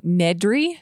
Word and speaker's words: Nedry: Nedry? – Yep.